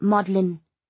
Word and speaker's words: Magdalen: It's pronounced Magdalen, Georgina corrected.